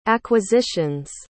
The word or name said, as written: acquisition's